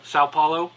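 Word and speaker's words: São Paulo: Sao Paulo.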